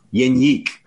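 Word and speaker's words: Jenik: To me, you saw Jan Janik in the lineup last night, and Janik. What are we doing? – Janik. Okay, this is his own pronunciation. I literally asked him how he pronounced Janik. His last name. Janik.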